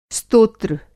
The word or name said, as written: स्तोत्र